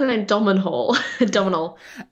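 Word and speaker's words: Domhnall: So it's got Donald Gleeson in. I'm saying that right, not Donald, as I repeatedly like to call him, and Jack's like, it's not Donald. – I think I call him Domenhall.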